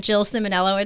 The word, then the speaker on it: Jill Ciminiello: You can find me on TikTok, Instagram, Twitter, LinkedIn, YouTube, all these things at Jill Ciminiello.